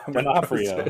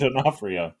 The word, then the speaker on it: D'Onofrio: Another thing that I thought was cool was Vincent D'Onofrio. D'Onofrio. – No, it's Vincent Donut Hole. – Vincent, I'm not going to say it right. I don't know why. – D'Onofrio. Vincent D'Onofrio.